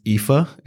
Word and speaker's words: Aoife: The wedding planner, I had to look up how to say her name. It's Aoife. It was like A-O-I-F-E. It's an Irish name.